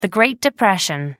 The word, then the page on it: great depression: British English